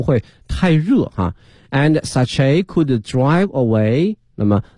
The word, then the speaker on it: sachet: 它的拼写呢是 s-a-c-h-e-t 但是呢在发音的时候这个 t 呢是不能发音的哈它的发音有两个一个是 sachet